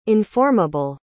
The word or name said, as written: informable